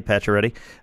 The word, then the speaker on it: Pacioretty: Pacioretty, did I say that right? – Pacioretty.